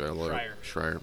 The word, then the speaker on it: Schreier: Jason Schreier, I believe. Schreier, sorry. Yeah. I always mispronounce the name.